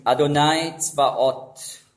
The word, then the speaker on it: Adonai Tzvaot: Auf Hebräisch ausgesprochen Adonai Tzvaot.